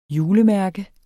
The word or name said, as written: julemærke